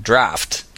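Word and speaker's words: draught: That's what's especially confusing is it can be thought, but it's but it can also be pronounced as in draft. – There you go. – You can see draft spelled with a G-H-T. – Yeah, G-H-T. So I think you just have to be aware that sometimes it's silent and sometimes it's pronounced F.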